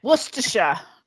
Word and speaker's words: Worcestershire: Boy, I bet I butchered that. – Worcestershire. – That's it.